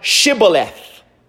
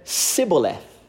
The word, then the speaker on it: Shibboleth: Then said they unto him, "Say now, Shibboleth." And he said, "Sibboleth." He can't say H, the letter H. For he could not frame to pronounce it right.